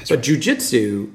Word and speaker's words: jiu-jitsu: And jiu-jitsu... am I saying it correctly? – Mm-hmm. Jiu-jitsu.